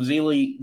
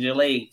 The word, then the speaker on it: Jilei: I know I would have gotten it right when I made the video on him, but Zhang Jilei. So it's not a Z. You're pronouncing it. You're not pronouncing it with a Z. You're pronouncing it. No, it's a J. Zhang Jilei.